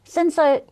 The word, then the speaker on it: Cinsault: Cinsault, you don't even spell it like that. It should be S-I-N-S-O. – The fact that it's spelled C-I-N. – But it's not S-A-U-L-T.